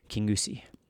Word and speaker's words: Kingussie: King Usi. I had to look that up on YouTube to make sure I got the right pronunciation.